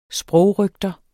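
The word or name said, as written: SPROGRØGTER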